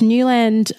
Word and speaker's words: Neuland: But Dana, the trainer, was telling me about these Newland pens that she uses, which are refillable. So if you are eco-conscious, check out Newland. It's spelt N-E-U-L-A-N-D.